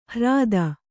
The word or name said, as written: ಹ್ರಾದ